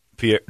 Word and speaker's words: Pierre: Pierre, no. – You're right. – Pierre is only one syllable, right? – Pierre.